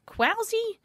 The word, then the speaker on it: Kwaussie: K-W-A-U-S-S-I-E. So K-W then Aussie.